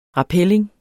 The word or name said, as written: rappelling